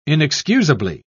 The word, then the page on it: inexcusably: British English